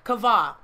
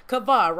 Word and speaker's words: kavah: Kavah. That's how it's pronounced, K-A-W-B-A-W, if you want to go do your research on it, but it's pronounced kavah, right?